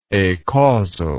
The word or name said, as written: acausality